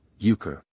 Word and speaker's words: Hooker: Mr. Brooker had a queer way of pronouncing his name, without the H and with a long U, Euchre.